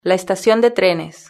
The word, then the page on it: la estacion de trenes: European Spanish